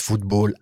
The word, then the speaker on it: football: Mais il y a quand même cette petite particularité, en français, on prononce football à l'anglaise et handball à l'allemande.